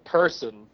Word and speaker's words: Purson: And then the second opener was this band called Person, spelled with a U instead of an E, so P-U-R-S-O-N.